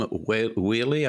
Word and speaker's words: Wehle: And Sabrina, I read Kim Wheely, I believe you pronounce her name, W-E-H-L-E,